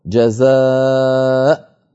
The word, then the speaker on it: جزاء: أول مثال عندنا في اللوحة في سورة الحشر قوله تعالى جزاء الظالمين، جزاء كتبت الهمزة على واو، فالواو كرسي للهمزة فلا تنطق هذه الواو لا وصلا ولا وقفا. ففي الوصل نقول جزاء الظالمين، وفي الوقف نقول جزاء بهمزة ساكنة.